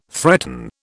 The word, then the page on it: threaten: British English